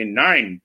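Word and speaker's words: nein: Then, you know, my son Travis taught me when his cat would do something wrong, he would say nine. So I looked this up. And so that is the German pronunciation of nine.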